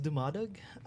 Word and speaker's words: Dumadag: It's pronounced Dumadag.